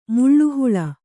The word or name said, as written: ಮುಳ್ಳುಹುಳ